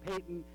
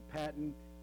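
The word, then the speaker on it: Paton: Some people pronounce his name Peyton, some people pronounce it Patton. Maybe in a Scottish brogue, it's somewhere in between, I don't know.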